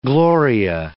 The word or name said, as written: gloria